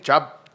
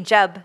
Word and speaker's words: Jubb: Am I saying it right? – Jubb. – Annie Jubb. – J-U-B-B. Jubb.